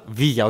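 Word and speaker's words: Villarreal: Βίλα Ρεάλ, Βίγια, οκ, διαβάζεται στην Ισπανία. Σεβίλα, Σεβίγια. Ναι, αλλά γράφεται και το ίδιο.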